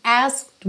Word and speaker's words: asked: É muito interessante esse asked why, asked why, porque você não pronuncia a letra E, você não fala asked why, asked why. Então é como se você fosse mesmo direto do K para o D.